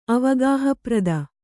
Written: ಅವಗಾಹಪ್ರದ

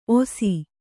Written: ಒಸಿ

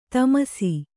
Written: ಥಮಾಸ

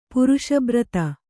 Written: ಪುರುಷಬ್ರತ

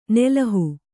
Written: ನೆಲುಹು

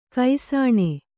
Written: ಘೋಸನೆ

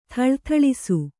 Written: ಥಳಥಳಿಸು

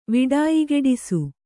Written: ವಿಡಾಯಿಗೆಡಿಸು

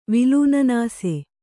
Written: ವಿಲೂನನಾಸೆ

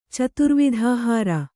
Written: ಚತುರ್ವಿಧಾಹಾರ

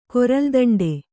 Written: ಕೊರಲ್ದಂಡೆ

